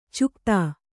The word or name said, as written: ಚುಕ್ತಾ